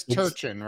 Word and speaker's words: Turchin: And it is Turchin, right? Am I saying that right? – Yep, I usually say it's like sea urchin with a T.